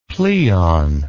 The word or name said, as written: pleon